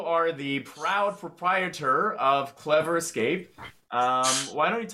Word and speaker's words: Clevr: Um, but we're called Clevr, which is spelled C L E V R, because we also have virtual reality uh, stations as well.